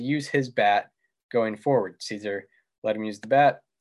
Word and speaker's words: Szczur: And Matt Szczur, am I pronouncing it correctly? Yep, it's Szczur.